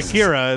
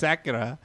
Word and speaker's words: Acura: Because I always called it Akira, and that's wrong. It's Acura. – Acura. – Yeah, you would like Acura, too.